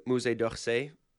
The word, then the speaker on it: Musée d'Orsay: And then they took us from there to Musée d'Orsay, and because they were that was it used to be the train station. – I give you an A+ for pronunciation.